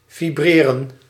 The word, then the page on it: battre: [batʁ]; France